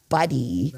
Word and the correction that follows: Baddie: right? Baddie. – Not our beautiful body. Baddie.